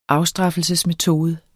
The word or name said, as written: afstraffelsesmetode